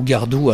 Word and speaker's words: Gardot: Deuxième personnalité, là on a eu la confirmation il y a très très peu de temps, c'est Mélodie Gardot, prononcée Gardot ou Gardou à la version américaine.